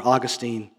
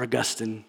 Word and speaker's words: Augustine: The great writer, the great church leader, church father, Augustine, or Augustine, depending on your pronunciation.